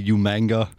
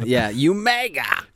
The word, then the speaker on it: Umaga: And I think that's what Umaga, however you pronounce it. I think didn't Regal always say Umanga? – Yeah. Umaga.